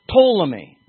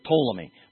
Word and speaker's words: Ptolemy: Ptolemy, and that's the way you say it in Greek, all right? You pronounce that P. You'll hear it Ptolemy.